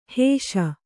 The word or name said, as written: ಹಿಶೆ